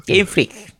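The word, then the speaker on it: Game Freak: 원래 마지막 크 발음은 약하게 합니다. 게임 프리 이렇게 합니다.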